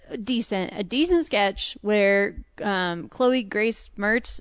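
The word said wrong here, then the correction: Moretz: is that how you say her name? – Moritz.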